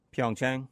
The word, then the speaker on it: Pyeongchang: Apparently, they went with the Trojans in Pyeongchang. Is that how you say it? Where the Olympics is? – Pyeongchang. – Is it Pyeongchang? – Yeah.